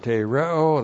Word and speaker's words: tereo: And that is our verb tereo. That's where we stopped last time. That's where we start this evening. Tereo, great verb. T, long E, R, E, long O. Tereo.